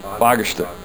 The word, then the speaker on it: Fagersta: the guys over in Seco in Fagersta, and I hope I pronounced that right. No? He's laughing over here. Oh, God. Okay. – Oh, God. How is it pronounced? – Fagersta. Fagersta. Okay.